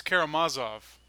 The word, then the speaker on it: Karamazov: not like the Z in Mozart." – Oh, so it's Karamazov.